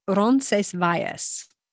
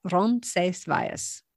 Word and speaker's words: Roncesvalles: So I imagine going all the way to, how do you say Roncesvalles? – The Spanish pronunciation is Roncesvalles. – Roncesvalles. – Yeah. They say it much faster, but to break it down, Roncesvalles.